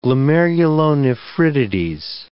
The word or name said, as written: glomerulonephritides